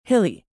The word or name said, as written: hilly